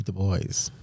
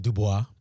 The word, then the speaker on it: Du Bois: Du Bois? – No. – I recommend you read Souls of Black Folk, W.E.B. Du Bois. – Mm-hmm. – It's spelled Dubois, but we pronounce it Du Bois.